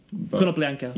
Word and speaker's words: Konoplyanka: Konoplyanka.